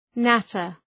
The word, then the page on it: natter: ‘nætər